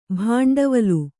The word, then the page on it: ಭಂಡವಾಳ: bhaṇḍavāḷa